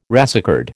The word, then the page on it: racecard: American English